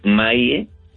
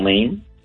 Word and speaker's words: Maie: Esta organización tiene sus oficinas en el 7730 de la calle Maie. – ¿Cómo se escribe? – M-A-I-E, Maie, no Main.